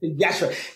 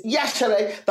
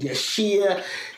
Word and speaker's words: Yashere: Yashere, because the I is almost is Yashere. The I is silent. So I dropped the I to help people pronounce it properly, and they still mispronounce it for a long time. I get called Yashir, Yashur, like oh. So I have to make I've got this thing now. I go it's Yashere. Think Yash. Hooray!